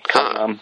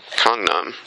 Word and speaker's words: Gangnam: Gangnam. It's kind of like somewhere between a G and a K. Gangnam. – Gangnam.